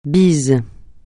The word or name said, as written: bise